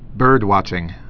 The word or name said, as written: bird watching